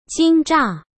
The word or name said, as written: Jinzha